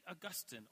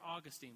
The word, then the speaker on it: Augustine: Consider the guy who we talked about last week, St. Augustine, or Augustine. We says you could pronounce it either way.